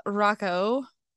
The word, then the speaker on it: Rocco: I have Rabecca Rocco. Rocco? Did I say it wrong again? – No, you got it.